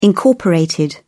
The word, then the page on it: incorporated: British English